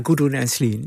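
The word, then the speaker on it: Gudrun Ensslin: Ja, Gudrun Enslin.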